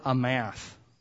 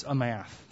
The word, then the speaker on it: amath: That term stand still comes from the Hebrew verb amath. Okay? It's pronounced like if you had an A and then you, you said the word math, like mathematics. It's amath. That's how you say it.